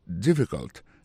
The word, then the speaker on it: difficult: Is it difficult? Difficult là, ça veut dire difficile. Lié plus comme ça, D-I-F-F-I-C-U-L-T. Difficult. N'a pas remarqué que accent tombé sur le premier syllabe là.